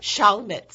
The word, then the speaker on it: Chalmette: Chalmette schools, mm-hmm.